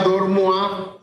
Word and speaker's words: Dormoy: Se pronuncia Dormua, se escribe Dormoy.